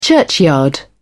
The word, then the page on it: churchyard: British English